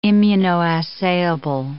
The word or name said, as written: immunoassayable